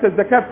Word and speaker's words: الزكاة: كلمة الزكاة تكتب دائما بالواو وتنطق بالالف، زي الربا، زي الصلاة، وهكذا.